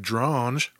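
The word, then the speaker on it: Dronge: In 1952, our man Alfred Drange, remember him? – Yes. Rhymes with orange.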